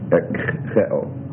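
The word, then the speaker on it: Ek-che-o (ἐκχέω): Oh, it's ek, it's ek, epsilon kappa, chi, epsilon omega. Ek-che-o, okay? A little hard to pronounce a kappa and a chi together.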